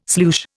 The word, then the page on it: sloosh: Indian